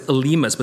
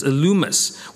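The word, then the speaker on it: Elymas: That's how you say it in the Greek. I know it looks like it's Elimus, but it's Elumus, which sounds like illuminate, which is what the word means.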